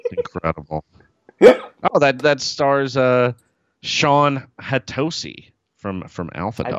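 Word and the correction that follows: Hatosy: I believe it's pronounced Hattacy. – Hattacy.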